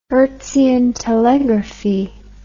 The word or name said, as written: Hertzian telegraphy